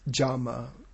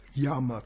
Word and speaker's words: yama: The first limb is known as jama, or yama commonly, Y-A-M-A. But, you know, in Sanskrit, when the Y comes at the beginning of the word, it should be pronounced as a J, so jama.